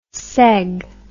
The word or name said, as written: seg